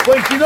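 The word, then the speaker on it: Foenkinos: Et notre invité est l'excellent David Foyquino. – Bravo. – Bravo. Merci. Vous l'avez très bien prononcé.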